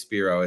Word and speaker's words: Spiro: Oh, it is Spiro.